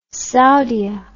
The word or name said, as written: Saudia